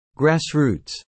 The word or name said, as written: grassroots